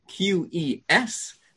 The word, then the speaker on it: Touques: The printed Tosfos on the page is usually the Tosfos of Tuch, Touques. That is how you say Tuch in French.